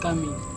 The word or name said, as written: kami